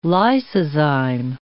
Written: lysozyme

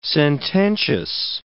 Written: sententious

